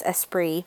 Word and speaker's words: Esprit: It's spelled E S P I R I T people would say E Spirit, but I believe it's um, it's European. It's Esprit, and I used to have tons of their stuff when I was younger.